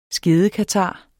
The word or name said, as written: skedekatar